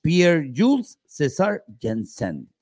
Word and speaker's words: Pierre-Jules César Janssen: El francés Pierre-Jules César Janssen. Ah, lo dije bien, Carlos. – Sí, lo dijo muy bien, lo pronunció muy bien.